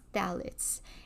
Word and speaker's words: phthalates: So number one are phthalates. They're actually spelled P-H-T-H. phthalates. So it's pronounced phthalates, and you'll see them in things like deodorants, nail products, hairsprays, shampoos, soaps, lotions, makeup.